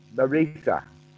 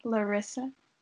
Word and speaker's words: Larissa: Larissa. With the short I, Larissa.